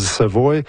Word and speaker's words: Savoy: Je sais pas s'ils les prononcent Savoy, mais en Louisiane, on dit Savoy.